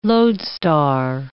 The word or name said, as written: lodestar